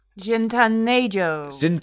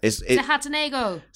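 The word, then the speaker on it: Zihuatanejo: Zihatanejo. – I can't remember. – Zihatanejo. – Yeah, Zihatanejo, a Mexican coastal town.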